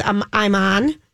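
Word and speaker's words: Iman: Dwayne Wade is on with I'm On.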